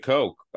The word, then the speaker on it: Koch: They're not either of those, because it's spelled K-O-C-H, the Koch brothers. – Ah, the Koch brothers. – God. But no, it is Coke. They pronounce it Coke.